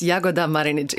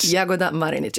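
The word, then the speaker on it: Jagoda Marinić: Man sagt es Jagoda Marinic, immer kurz. – Jagoda Marinic. – Jagoda Marinic, immer Initialakzent, wie das so schön heißt. Also vorne die ersten Silben, okay. Jagoda Marinic. 95% der Kroaten beginnen mit Initialakzent.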